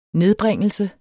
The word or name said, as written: nedbringelse